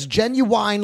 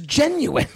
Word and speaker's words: genuine: I go, genuine leather, like the R&B singer. – And he goes, what? Who told you to say it like that? He goes, that's the way my teacher says it. Yeah. It's genuine leather. He goes, the word is genuine.